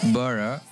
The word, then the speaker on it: Borough: No sé cómo se pronuncia, mira, a ver si os lo voy a poner. – ¿Empieza por B? – Sí. – Borough. – Así se pronuncia, que es un mercado de comida que está bajo las vías del tren y son todo puestos callejeros y es un ambiente...